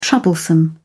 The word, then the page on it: troublesome: British English